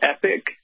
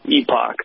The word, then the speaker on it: epoch: So the technical American pronunciation is Epic, but if you want to speak the Queen's English, you want to sound fancy, Commonwealth country, Epoch.